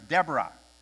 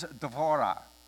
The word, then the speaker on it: Devorah: Because I grew up thinking it was Deborah, but there's an O in there. And in Hebrew, it is Devorah, and it accents on the last syllable.